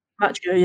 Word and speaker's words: Macchio: I love the fact that it's the original, you know, I'm going to butcher his name, Ralph Macchio. – Macchio, yeah.